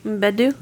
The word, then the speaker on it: Mbedu: Mbedu.